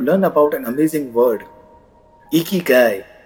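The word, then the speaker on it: इकीगाई (生きがい): First you have to लर्न to pronounce it. You have to lift your cheeks as if you are smiling and you have to से इकीगाई। से with me, come on, इकीगाई।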